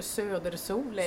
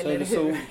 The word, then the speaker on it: Södersol: Ja, Söders OL eller Södersol. – Södersol. – Eller hur man nu ska uttala det. – Ja, det är Södersol.